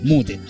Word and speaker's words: Mute: No sabíamos cómo pronunciarlo, lo pronunciamos como lo leímos, Mute. Estuvimos bien ahí entonces.